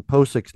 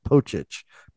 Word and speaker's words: Pocic: Think of like Stipe Miocic is CIC. – I can't do that. – Yeah, his last name is CIC, just like Ethan Posick's name is CIC. and his name is pronounced Miocic. So it makes sense that Ethan Pocic's name is also pronounced Pocic.